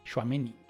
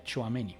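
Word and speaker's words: Tchouaméni: Shuameni. Se pronuncia Chuameni. Chuameni.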